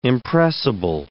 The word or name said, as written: impressible